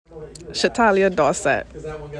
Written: Shatalya Dorsett

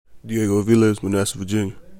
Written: Diego Aviles

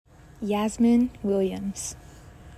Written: Yasmine Williams

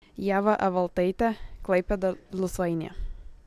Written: Ieva Evaltaite

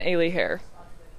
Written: Ailie Hair

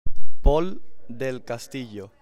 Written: Pol del Castillo

